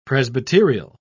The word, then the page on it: presbyterial: British English